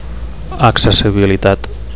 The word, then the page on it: accessibilitat: Catalan